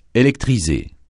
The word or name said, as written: électriser